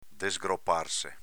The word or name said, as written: desgroparse